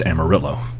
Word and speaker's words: Amarillo: But the Spanish word for the color yellow is amarillo, but the people who live in that town seem to insist that it is pronounced amarillo.